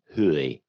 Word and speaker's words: hue: The word is spelled H-U-E. – Hooey. – Hooey. – Hooey. – That's a southern way of saying hue.